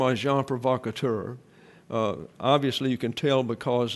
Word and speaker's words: agent provocateur: And so I'm telling you the way it's pronounced in all the dictionaries. Agent provocateur. And even when it's plural, it's provocateur.